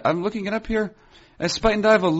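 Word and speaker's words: Spuyten Duyvil: S-P-U-Y-T-E-N and then D-U-Y-V-I-L. Spuyten Duyvel. I believe it's pronounced.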